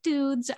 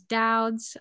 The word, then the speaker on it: Doud's: the, the main grocery store on the island is, is doud's is that it yeah, it's Doud's. A lot of people like to pronounce it dudes. I am here to correct you. It is Doud's.